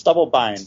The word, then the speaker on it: Stubblebine: Is that pronounced right? – It's Stubblebine. – Stubblebine. Right, that was it.